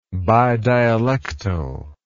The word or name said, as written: bidialectal